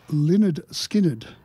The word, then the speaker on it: Lynyrd Skynyrd: Jeff's corrected me on how you say this name. It's Lynyrd Skynyrd.